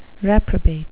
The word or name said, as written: reprobate